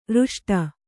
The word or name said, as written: ರಿಷ್ಟ